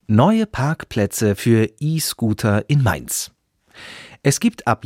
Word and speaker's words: E-Scooter: Die Stadtverwaltung von der Stadt Mainz sagt, E-Scooter müssen ab jetzt auf diesen Parkplätzen stehen. E-Scooter spricht man E-Scooter.